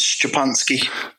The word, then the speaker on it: Szczepanski: How do you say your Yeah, my, my last name's Polish, so it's Szczepanski.